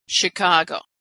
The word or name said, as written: Chicago